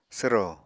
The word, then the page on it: swro: apatani